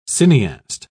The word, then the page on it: cineaste: British English